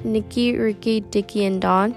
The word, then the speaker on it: Nicky Ricky Dicky and Don: Nicky Ricky Dicky and Don.